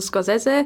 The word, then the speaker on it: Scorsese: Wir sagen Scorsese, obwohl, glaube ich, die korrekte eher so Scorsese ist. – Obwohl, wir wissen, dass es italienisch anders ausgesprochen wird. Aber wir sind Martin Scorsese. Wir sind eher amerikanisiert als italisiert.